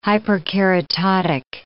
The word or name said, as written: hyperkeratotic